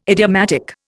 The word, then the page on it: idiomatic: Indian